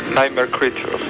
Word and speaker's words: Nightmare Creatures: Es Nightmare Creatures, aunque la verdad es que mi inglés es bastante de Cáceres, así que vamos a pedir a nuestro colaborador esencial que traduzca realmente cómo se pronunciaría esto. Por favor, ¿cómo se traducen las criaturas de las pesadillas estas? – Nightmare Creatures. – Ahí está. Es impresionante.